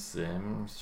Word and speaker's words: Siems: How do you spell it? – S-I-E-M-S. Sims? – Sims? Sims, sure.